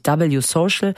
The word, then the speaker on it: W Social: Ein Gegenentwurf zu X, vormals Twitter, ist am Start, nennt sich W Social. Also am Anfang steht der Buchstabe W auf Englisch ausgesprochen. Und die Geschäftsführerin von W Social,